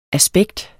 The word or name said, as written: aspekt